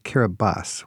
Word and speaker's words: Kiribati: Hey, by the way, it's spelled Kiribati. We pronounce it Kiribati.